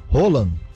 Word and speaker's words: Haaland: Se escreve Haaland, mas pronuncia Roland, né? O pessoal fala Haaland porque está escrito Haaland, mas é Roland, o nome, um alemão alto, né?